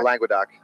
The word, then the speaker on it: Languedoc: How you spell, pronounce it? Languedoc? – Languedoc.